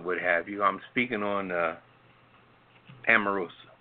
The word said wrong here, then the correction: Omarosa: Is that how you pronounce her name? – Omarosa.